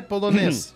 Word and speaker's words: полонез: Ну, дансе полонез. да, понятно. Полонез. – А, ну, ударение на последнем во французском всегда.